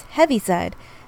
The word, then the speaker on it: Heaviside: You said Heavenside, and the correct term is Heaviside. The reason I know this is because it's not only spelled Heaviside, but also it's said in the song.